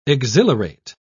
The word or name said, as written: exhilarate